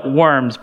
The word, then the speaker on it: Worms: It's spelled like worms, but they don't have W's, they use V's, so it's Worms.